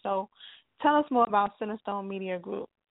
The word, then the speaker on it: CentrestoneMedia Group: Now, you talked earlier about being the CEO and owner of, I hope I'm saying it right, CentrestoneMedia Group.